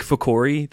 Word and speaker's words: Ficori: So I'm Nick Ficori. That's how you say it. It's okay. Yeah, it looks just like it says. It sounds just like it looks.